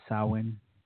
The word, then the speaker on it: Samhain: It's spelled Samhain, but it's Gaelic, so it the pronunciation is Samhain, and it's a Celtic festival.